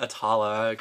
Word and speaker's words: Atala: So I'm gonna call it Atala, how it should be pronounced.